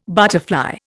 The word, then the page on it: butterfly: Indian